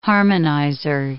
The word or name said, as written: harmonizer